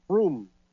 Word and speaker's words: Frome: Froome, F-R-O-M-E. They pronounce it Froome.